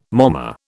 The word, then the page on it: momma: British English